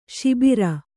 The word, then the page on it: ಶಾಬರ: śabara